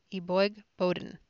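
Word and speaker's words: Eboigbodin: 2, Boden, excuse me, I knew I was going to mispronounce that. It's E-B-O-I-G, B-O-D-I-N, Eboigboden, shines in breakout performance.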